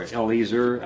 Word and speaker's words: Eleazar: Eleazar, or Eliezer, I think is normally how it's pronounced in Hebrew, to keep the Ark of the Lord.